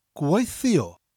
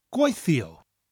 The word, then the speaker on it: gweithio: The first part of gwaithio is pronounced exactly the same as gwaith, but the spelling is slightly different. Gwaith is G-W-A-I-T-H, and gwaithio is G-W-E-I-T-H-I-O. So we have the gwaith sound followed by E-O, gwaithio.